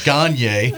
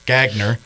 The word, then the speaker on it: Gagne: It looks like Gagne. No, it's Gagner.